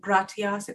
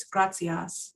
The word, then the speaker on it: gratias: And T-I's are a little bit different too. Instead of gratias, it's gracias. – Gracias.